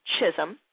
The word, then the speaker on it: Chisholm: Carla Chisholm, the L is silent, .com, and you can get tickets from the website.